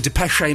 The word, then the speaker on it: Depeche: Now, Eloise, before we play the Depeche mode, there is an accent over the second E, so you do say... the third E, so you do pronounce it Depeche, I believe.